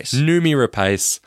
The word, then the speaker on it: Noomi Rapace: Noomi Rapace.